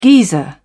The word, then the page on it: geyser: British English